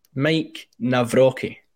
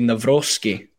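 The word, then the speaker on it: Nawrocki: Yeah, it was, I googled how to pronounce Mike Navrocki. – Navrocki. – Although we had a automated woman's voice saying Navrocki. So Navrocki, however you want to pronounce it. I think We'll go Navrocki. – Yes, it's a bit easier.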